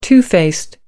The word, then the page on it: two faced: American English